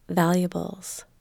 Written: valuables

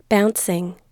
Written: bouncing

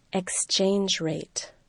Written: exchange rate

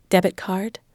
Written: debit card

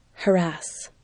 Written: harass

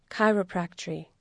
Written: chiropractry

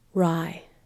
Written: rye

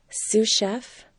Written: sous chef

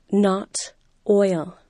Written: not oil

